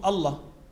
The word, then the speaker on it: Allah: Even if they emitted the alif, because when we write the term Allah, it is alif, lam, lam, and ha. But we pronounce it as Allah. So there is an alif, which is not written.